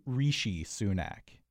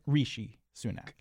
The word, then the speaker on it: Rishi Sunak: Now, I think it's Rishi Sunak. – Correct. – Is that right? Rishi Sunak.